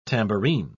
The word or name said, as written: tambourine